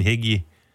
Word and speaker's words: Hegyi: I assumed when Christian Hegyi, am I pronouncing that right, Andy? – Yep.